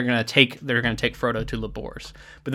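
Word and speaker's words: Lugbúrz: So there's a lot of talk about this Labor's person who, I'm only pronouncing it that way because this is in the audiobook, which I listened to for part of this, but it's spelled Lugburz, but that's Labor's apparently.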